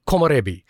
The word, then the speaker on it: komorebi: But the word is korambi, I believe it is. And apologies for my mispronunciation there. Or komorebi.